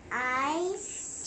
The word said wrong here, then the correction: isn't: 这个要念成 isn't。